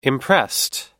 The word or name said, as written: impressed